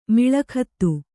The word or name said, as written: ಮೇಳಗೀತ